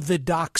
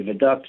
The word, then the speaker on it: Vidocq: The Vidocq Society and it's debatable how you pronounce it. I've spoken to French people, and they say uh, Vidoc, some say Viduc, so it's, we call it the Viduc Society.